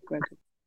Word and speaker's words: Grogu: That's how you say it, right? Grogu. – You got that right, at least. Yeah, you got the pronunciation.